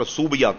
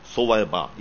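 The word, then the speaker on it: ثویبہ: وہ یہ ہے کہ ابو لہب نے حضور علیہ السلام کی پیدائش کی خوشی میں اپنی لونڈی جس کا نام صعیبہ تھا, جس کا تلفظ لوگوں نے بگاڑ کر صوبیہ کر دیا, اصل تلفظ ہے صعیبہ, یہ سا سے.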